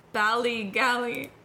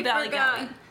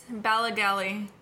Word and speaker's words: Ballygally: So, I am gonna talk about Ballygally. That's not how you pronounce it. I googled it. – It's definitely Ballygally. – It's Ballygally. Ballygally.